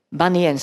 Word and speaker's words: Bunyan: Bunyan sa to vyslovuje. Hej, Slováci hovoria Bunyan.